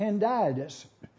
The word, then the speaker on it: hendiadys: It is pronounced endiadas, but here's the way you spell it. H-E-N-D-I-A-D-Y-S.